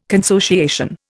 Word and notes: consociation: Indian

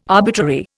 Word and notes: arbitrary: Indian

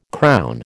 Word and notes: crown: American English

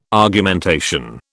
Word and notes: Argumentation: British English

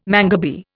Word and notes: mangabey: Indian

